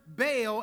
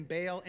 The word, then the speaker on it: Baal: By the way, I'm going to go back and forth between saying Baal and Baal, because if you're reading through in Hebrew, you pronounce it Baal, but it can get a little cumbersome sometimes, so I might say Baal, okay?